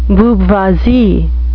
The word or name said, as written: booboisie